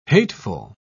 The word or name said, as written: hateful